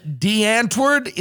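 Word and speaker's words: Die Antwoord: D'Antwoord.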